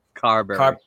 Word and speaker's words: Carberry: You know, we talk about Carberry. I'm probably saying his name wrong because my mouth sucks. – Carberry. – Thank you. I add a D in there or an R somewhere because I'm weird.